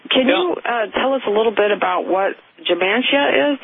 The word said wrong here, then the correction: Gematria: Is that, am I pronouncing that right? The number system that the occult frequently uses? – Yeah, Gematria, they assign a number value to a letter and then add that up.